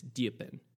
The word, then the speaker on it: Dupin: I think it's pronounced, and I might pronounce this wrong because it's technically French, Diepin, spelled D-U-P-I-N. It's Diepin.